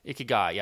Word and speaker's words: Ikigai: Ikigai. – Yeah, Ikigai.